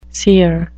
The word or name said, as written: sere